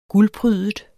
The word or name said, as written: guldprydet